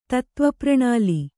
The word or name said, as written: ತತ್ತ್ವಪ್ರಣಾಲಿ